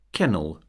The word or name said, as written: kennel